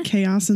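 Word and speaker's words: caos: So chapter one is titled Chaos. Is that how you pronounce it? – Yeah. Chaos in Spanish.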